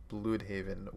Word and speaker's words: Blüdhaven: We flew all the way from Gotham and we are now in Blüdhaven with Nightwing and Brandon's going to tell us all about that one.